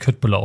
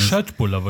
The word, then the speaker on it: köttbullar: Schöttbullar wird es ausgesprochen.